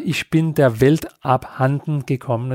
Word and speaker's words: Ich bin der Welt abhanden gekommen: øh, den der hedder Ich bin der Welt abhanden gekommen, er det rigtigt udtalt? – Præcis.